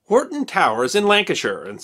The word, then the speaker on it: Hoghton: The family first took the name Horton, spelled H-O-G-H-T-O-N, but pronounced Horton, in 1150.